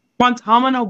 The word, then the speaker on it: Guantanamo: Guantanamo Bay. – Guantanamo Bay.